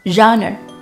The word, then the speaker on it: genre: 读这个单词的时候呢，一定要口型圆润，读作 genre，发 r。这个音的时候，先把嘴巴撅起来，再往回收，有点类似日的音。